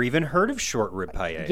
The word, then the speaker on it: paella: And then they're known for their, and I hope I'm saying it right, because paella? Am I saying it right? – Yeah, the paella.